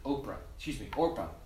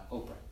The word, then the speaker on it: Orpah: By the way, Oprah's name, she was originally named Orpah, but everybody mispronounced it Oprah, so she's Oprah now.